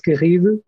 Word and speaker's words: Garrido: So if you want to look me up on LinkedIn, I'm Duarte Garrido. This is hard to pronounce, but it's easier to search. D-U-A-R-T-E.